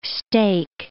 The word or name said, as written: steak